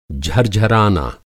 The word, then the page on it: झरझराना: Hindi